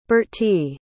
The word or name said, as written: birtie